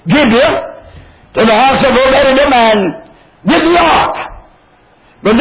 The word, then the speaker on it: Obed-Edom: Some have pronounced it Obed-Edom, but the correct pronunciation after looking it up again is Obed-Edom.